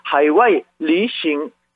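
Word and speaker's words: 하이와이, 리싱 (海外旅行): 발음은 하이와이, 하이와이. 여행은 리싱, 리싱. 그것도 비슷하죠? 그래서 해외여행, 다시 발음하면 하이와이, 리싱. 해외여행, 하이와이, 리싱이었습니다.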